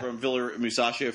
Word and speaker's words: Musacchio: Is it Musacchio? Is that how you say his name? – Yeah. – Musacchio from Villarreal.